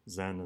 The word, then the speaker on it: Zen: Zen is the Japanese pronunciation of Chan.